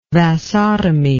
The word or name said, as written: vasotomy